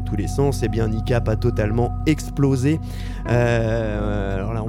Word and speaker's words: Kneecap: Il est le coup de cœur de la semaine, qui, cette semaine, ne pouvait être autre que le nouvel album de NICAP. Alors, ça s'écrit K-N-E-E-C-A-P.